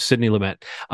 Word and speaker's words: Sidney Lumet: But if anybody has ever said his name publicly, like in an award ceremony or an interview, they say Lumet. So I'm going back to Lumet,